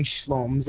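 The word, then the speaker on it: Shloms: Yeah, so this digital performance artist, I think he pronounces his name Schloms, it's S-H-L-O-M-S anyway,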